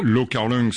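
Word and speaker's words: Lau Kar-leung: Lo Kar-leung, c'est la prononciation cantonaise, et Liu Xia-liang, c'est la prononciation mandarin, il est connu par les deux titres.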